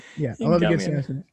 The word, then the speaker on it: Sasquatch: Is that how you pronounce Sam Squanch? – I mean, you know, like a humanoid, very hairy, like Bigfoot. I think people say casually. – Say that word again. – Sam Squanch. – I feel like you've given me shit so many times about how I pronounce words. I think this is my moment. You mean Sasquatch? – No, nope.